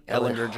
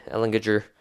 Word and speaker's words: Ehlinger: I mean, Ehlinger, hard, hard G, Ehlinger.